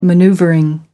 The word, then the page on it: manoeuvring: American English